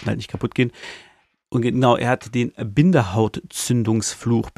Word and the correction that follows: Bindehautentzündung: Bindehautentzündung, nicht Bindenhaut, Bindehautentzündung.